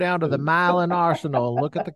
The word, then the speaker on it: Milan: In Tennessee, where my wife is from, they have a city called, it's spelled Milan, like in Italy. That's Right. – not how they say it. They say Milan.